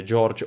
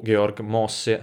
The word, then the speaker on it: Georg Mosse: George o Georg Mosse, a seconda se volete usare la pronuncia americana di quando si trasferì in America,